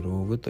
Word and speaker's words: LOG: えー、札幌は中、えー、北区ですね、北区の、えーと、北十四条西 3 丁目にありますローグ LOG と書いてローグという、